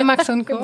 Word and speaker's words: Max & Co.: Habe ich es richtig ausgesprochen? – Ja, Max & Co.